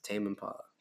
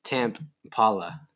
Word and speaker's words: Tame Impala: Yes, Tame Impala. Tame Impala. Either way, it's Tame. Hobby. It's pronounced Tame. – Tame Impala.